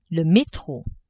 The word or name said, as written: metro